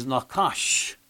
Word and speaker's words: nakash: And so in verb form, the takes on a short A, but it's still the same word, nakash, nakash.